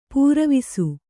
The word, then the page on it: ಪೂರವಿಸು: pūravisu